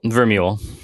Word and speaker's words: Vermeule: And Adrian how do you say his name? Vermeule? – Vermeule. I believe.